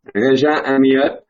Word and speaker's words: Régis Amiot: His name is Régis Amiot, or let's get this French pronunciation correct. Régis Amiot. Is that right, Régis? – Régis Amiot. That's close enough.